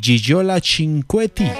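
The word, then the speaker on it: Gigliola Cinquetti: Y Gigiola Cinquetti, o Gigiola Cinquetti, como se escribe, así dicen muchos.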